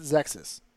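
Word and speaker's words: Xexyz: It's X E X Y Z, and it is a banger. – How did you pronounce it? – I always pronounced it Zexys.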